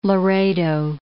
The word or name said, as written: Laredo